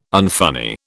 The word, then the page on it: unfunny: British English